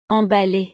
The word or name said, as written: emballee